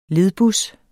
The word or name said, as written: ledbus